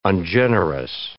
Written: ungenerous

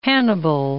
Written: Hannibal

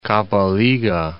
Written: Kabalega Falls

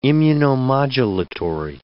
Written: immunomodulatory

